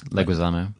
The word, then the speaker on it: Leguizamo: John Leguizamo's Leguizamo.